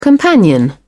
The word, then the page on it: companion: British English